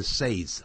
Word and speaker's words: says: He can be wrong if he says so，如果他这样说的话可能有错。不要念成 says 啊，念 says，原形动词念 say。